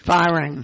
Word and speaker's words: Fearing: And there's something else going on in Georgia that is going to be the subject of our conversation with Alice Fearing. – Firing. – And we should say Alice totally fireless. I wondered if it rhyming with fearless.